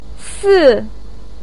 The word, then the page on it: si4: pinyin